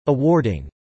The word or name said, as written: awarding